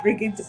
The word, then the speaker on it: Brigid: And by the way, when you're dealing with Bridget, you're not talking B-R-I-G-I-T, which is how we spell it in America or in England. It's Brigid, B-R-I-G-I-D.